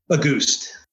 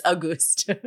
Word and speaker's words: Auguste: Yeah, I was gonna say, because is it Gus Auguste? How do you say that? – Auguste. – I'm like, I've, there's probably 5-6 episodes where I'm just like murdering that. And now we know it's Auguste.